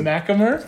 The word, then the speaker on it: MacHamer: I'm here with my man, Brendan MacHamer. MacHamer. – But, you know, he's close. – Is that how you say it? – Yeah. The H is silent. It's capitalized, but it's silent.